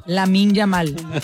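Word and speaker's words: Lamine Yamal: Lamin Yamal.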